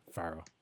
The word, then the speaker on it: Farao: Is that how you say it, Umang? Faro? – Yep. – Faro. F-A-R-A-O.